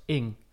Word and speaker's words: Ng: Want haar, uh, haar Twitternaam, dus haar Twitter-handle, is gewoon pronounced Ing.